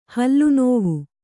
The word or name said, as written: ಹಲ್ಲುನೋವು